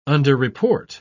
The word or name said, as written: underreport